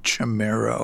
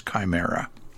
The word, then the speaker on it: Chimera: It's one of those things that you are challenged to pronounce it correctly, because it looks like it should be Chimera or Chimera or whatever, but it's actually, according to two dictionary references I checked, it's Chimera.